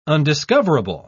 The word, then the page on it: undiscoverable: British English